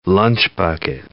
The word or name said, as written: lunch-bucket